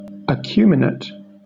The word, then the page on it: acuminate: British English